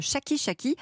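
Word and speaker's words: Shaki Shaki: Vous avez 38 ans, Alexandre, et vous êtes le cofondateur de Chaki Chaki. Alors, ça s'écrit S-H-A-K-I et plus loin S-H-A-K-I.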